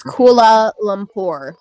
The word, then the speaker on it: Kuala Lumpur: Okay. Koala Lumpur. Against, Pagan... Koala. – It's Kuala Lumpur.